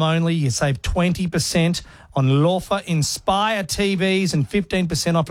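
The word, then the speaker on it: Loewe: When Lova were created, they never thought for a second that Aussies would be pronouncing their beautiful name Lova. – Or Lo. – You're right. – The way that it's spelt.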